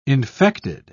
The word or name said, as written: infected